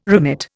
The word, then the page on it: remit: Indian